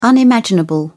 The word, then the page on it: unimaginable: British English